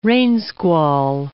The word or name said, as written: rainsquall